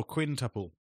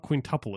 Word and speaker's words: quintuple: Yeah, who said, well, quintuple. – Yeah, he said it's like pronounced. – It's not quintuple, it's quintuple.